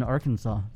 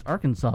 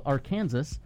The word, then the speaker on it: Arkansas: It's pronounced Arkansas, but some people call it Arkansas. – I see. – And it is illegal to pronounce it Arkansas.